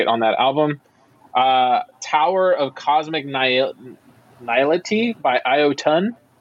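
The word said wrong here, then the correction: Iotunn: I don't know if I'm pronouncing this. – It's actually Yotun. – Oh, okay. So there you go, Yotun.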